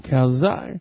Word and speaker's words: calzone: I think in Italian it is pronounced calzone. – Mm-hmm. I don't know. – But we're not in fucking Italy, dude. – Oh. – No. – So it's like Stallone, calzone.